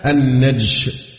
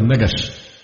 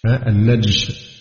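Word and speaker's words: النَّجْش: النوع الثامن من البيوع المنهي عنها النجش بتسكين الجيم، لا كما ينطق بعض الإخوة النجش، لا النجش، النجش بتسكين الجيم، وهو أن يزيد شخص في ثمن السلعة المعروضة للبيع ولا يريد شراءها، وإنما ليغر غيره بها ويرغبه فيها ويرفع سعرها.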